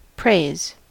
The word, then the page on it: praise: American English